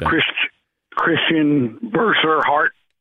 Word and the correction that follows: Bezuidenhout: Oh, Bezuidenhout? – Yeah. – Bezuidenhout, right.